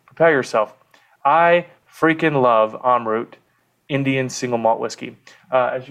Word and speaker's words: Amrut: I think if you're in India, it's pronounced Amrut. I bounce back and forth between the pronunciation.